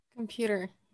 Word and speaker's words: computer: I don't pronounce it as computer. Да, я говорю come. То есть вот это это есть шва, и в конце слова он такой же computer. – Computer.